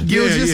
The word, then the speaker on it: Gilgeous: Gilgeous. Yeah, yeah. But you was right there. – Yeah, I'm sweating, but damn it, is it Gilgeous? – Gilgeous.